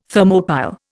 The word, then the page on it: Thermopile: Indian